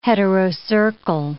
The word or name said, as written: heterocercal